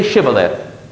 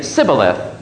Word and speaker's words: Shibboleth: And when he said, "No," they said to him, "Then say Shibboleth." And he would say Sibboleth, for he could not pronounce it right.